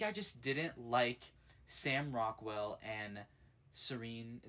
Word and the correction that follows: Saoirse: Is that how you say her name? – Yeah. – Yeah, um, Saoirse Ronan. Saoirse, oh my gosh, I just did not like Sam Rockwell and Saoirse Ronan's character enough to really yeah.